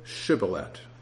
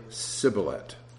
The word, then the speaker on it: Shibboleth: So when he said no, then they would say to him, "Then say Shibboleth." And the Ephraimite would say Sibboleth, for he could not pronounce it correctly.